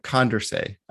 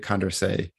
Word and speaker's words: Condorcet: So it's named after a French guy, Marquis de Condorcet, who was very interested in voting theory in his day. It's spelled C-O-N-D-E-R-C-E-T. The T is silent. Like those French folks, they don't like to pronounce the T.